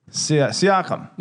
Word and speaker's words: Siakam: Was it Siaka or no, Pascal? – No. You didn't watch game one, did you? – Wait, Siaka. – Siakam. – Yeah. – Yeah. – I can't even fucking pronounce his name right.